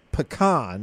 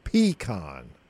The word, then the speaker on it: pecan: It's first of all, it's Detroit, not Detroit, and it's pecan, not pecan. So not so much I've emphasis on the first it, syllable.